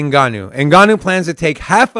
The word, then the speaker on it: Ngannou: Heavyweight champ Francis Nanu? Ngannou. – Brazo, how do you say that? – Right. Ngannou.